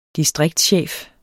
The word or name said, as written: distriktschef